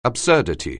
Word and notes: absurdity: anglais

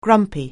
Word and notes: grumpy: anglais

